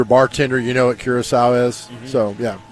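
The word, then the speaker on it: Curaçao: I think of everything that you've done, the fact that you actually pronounce Curacao perfectly. Thank you for that. – You're welcome. – How's Curaco doing? – No, not Curaco.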